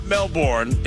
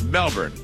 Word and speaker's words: Melbourne: It's not Melbourne, it's Melbourne. Or is it Melbourne? – Melbourne. Or Melbourne. Oh, Melbourne. Melbourne. Apparently they don't pronounce the R.